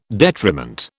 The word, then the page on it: detriment: British English